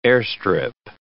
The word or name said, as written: airstrip